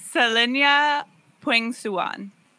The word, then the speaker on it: Selenia Pueng Suan: So please correct me in my pronunciation. Here we go. So... we have Selenia Pueng Suan. – Yes. – Exactly.